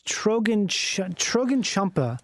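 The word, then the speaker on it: Chögyam Trungpa: Do you know about this monk named Trogen Chump? I always say his name wrong. He's a Buddhist monk. – Yeah. – And his name is Trogen Champa.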